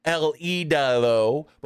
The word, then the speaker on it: El Idolo: Because no lead announcer in this company, or at least on Collision, can say El Idolo. Both Kevin Kelly and Ian Riccoboni butcher it, saying Andrade El Idolo. Andrade El Idolo. El Idolo. Idolido. Like, how hard is it to say Andrade El Idolo?